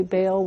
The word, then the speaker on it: Baal: What is exactly Baal worship? – Okay. Baal, which is technically the correct pronunciation of his name, as far as we know, was a god of originally of the people of Lebanon, with the basic idea that he brought and took away the storms.